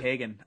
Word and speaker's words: Keoghan: Salma Hayek, Barry Keoghan. Like, I don't know if I'm pronouncing that correctly, but Keoghan. – Thank you.